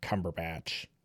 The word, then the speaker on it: Cumberbatch: Like, Benedict Cumberbatch. – I think you said it right.